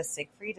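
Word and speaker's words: Seyfried: And I feel like in Mean Girls, Amanda Siegfried, is that how you pronounce her name? – Yes, it is.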